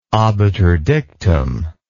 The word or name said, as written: obiter dictum